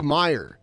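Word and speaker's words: Meijer: She like stars some scuff in the background, and it's spelled M E I J E R, I think. It's Meyer with a J.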